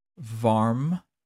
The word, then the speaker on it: vam: The mantra for it is Varm, V-A-R-M. That's how you pronounce it. It's actually spelled V-A-M.